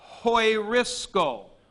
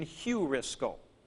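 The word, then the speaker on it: Hoirisco (εὑρίσκω): And so this is pronounced He'eron, and this is pronounced Hoirisco. Hoirisco, or for those of you who had different pronunciation, Heurisco.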